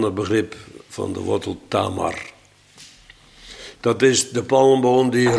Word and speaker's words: Tamar: Nou ja, Tomer zegt men dan, maar met die uitspraak hou ik me niet bezig. Het is Tamar en Tamar kennen we als de naam van een prinses uit het huis van David.